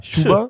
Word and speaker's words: Chuba: Ist es so ein Sch oder so ein. Sch Es ist ja CH. – Ja, Chuba. Ch. – Chuba. Ch. Chuba.